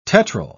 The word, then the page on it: tetryl: British English